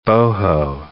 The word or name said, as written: boho